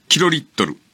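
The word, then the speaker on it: 竏: キロリットル？ – うん。 – 計 2 リットル。 – キロリットル。え、漢字で。 – え、漢字で？ – うん。 – わかりません。 – 立つという字に、千と書いて、これがキロリットル。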